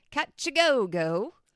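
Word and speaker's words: Kajagoogoo: So the way that they're pronouncing it was Catch-a-go-go.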